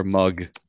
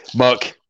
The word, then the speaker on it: Muck: Muck or mug? – Muck. Rhymes with fuck and suck. Duck. If you want to throw it in there, there's Chuck.